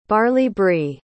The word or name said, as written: barley-bree